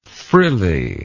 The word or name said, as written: frilliness